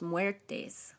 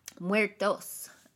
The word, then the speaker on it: Muertos: Dia de los Muertes. Muertos.